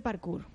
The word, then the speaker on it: parkour: Estoy leyendo aquí que también hacéis parkour. No sé si lo he dicho bien. – Parkour. – Vale. – Pero eso... – Yo intentaba hacerme la francesa.